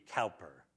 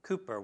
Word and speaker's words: Cowper: The way his name is spelled, it sounds like it would be Cowper, okay, if that rings a bell. William Cooper was a friend of, who's the guy that wrote, was it Amazing Grace?